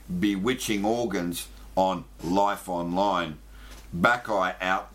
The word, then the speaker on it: Bacchae: Number one, Backeye, Pleasure Vision. Let me spell that for everybody. Backeye, B-A-C-C-H-A-E.